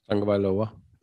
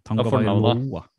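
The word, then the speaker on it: Tagovailoa: Og på tredjeplassen min, uh, og vi må jo prate litt om den kampen òg, så er det vår kjære Tua Tangavaloi Vloya. Er det sånn man uttaler det? – Tangavailoa. – Tangavailoa.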